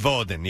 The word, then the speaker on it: Woden: Woden. I guess Woden. Yeah, yeah. That's how you'd say it.